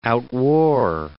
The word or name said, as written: outwore